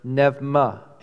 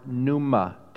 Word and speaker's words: pneuma: But that word spirit there is the word pneuma in Greek, or pneuma, depending on how you want to pronounce it. Do you want the old Greek or the new Greek?